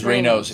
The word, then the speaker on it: Raynaud's: So not everybody knows what this Raynaud's, that's Raynaud's. How I know how to say it, is Raynaud's. Raynaud's.